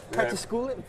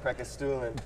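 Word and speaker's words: Preikestolen: Prekestulen. – Shout out Norway. Prekestulen.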